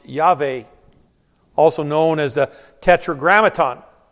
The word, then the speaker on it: Yahweh: The proper pronunciation would be Yahweh, God.